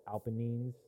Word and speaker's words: Apennines: Which is actually kind of cool because I'm actually learning about, like, Italy and, like, the Alps and then the Apennines.